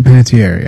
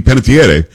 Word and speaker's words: Panettiere: I say Panettiere, yeah. – Panettiere. It's an Italian name. It'd be Panettiere.